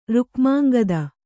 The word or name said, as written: ರುಕ್ಮಾಂಗದ